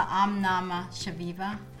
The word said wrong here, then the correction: Om Namah Shivaya: How do you say that again? – Om Namah Shivaya. – Om Namah Shivaya. – Perfect.